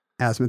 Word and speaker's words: asthma: In the dictionary, it just says asthma. It's the only word in English with a silent TH.